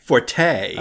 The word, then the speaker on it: forte: This is one of those weird things where the actual correct pronunciation in this sense is fort, um, but we are all used to hearing forte for this meaning, where mm-hmm. forte is actually a reserve just for the musical notation forte, meaning strong or loud.